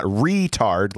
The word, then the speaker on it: retard: You don't put the emphasis on the E. It's on the A, so it's not retard like we would say. – It's retard. – It's retard. Yes, it is. And I don't know why, but that is the correct pronunciation as far as I'm aware.